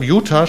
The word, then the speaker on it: Utah: Utah spricht man so aus, ja, und Nevada.